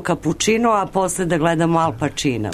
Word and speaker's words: Al Pacino: italijansko slovo C, odnosno udvojeno CC, nađe ispred samoglasnika E, odnosno I, izgovara se kao naše Č.